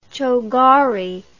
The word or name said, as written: chogori